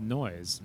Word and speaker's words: Noyes: And there's a stop there called Nois. – Mm-hmm. Oh. – But it's spelled N-O-Y-E-S.